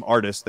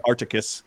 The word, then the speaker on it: Ardis: They just didn't know how to pronounce my name, Ardis. They called it Ardis. You know, they were trying. Oh. They got it wrong.